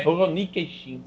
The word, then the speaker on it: Rurouni Kenshin: Não, Rurouni. É Rurouni Kenshin.